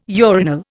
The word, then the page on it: urinal: Indian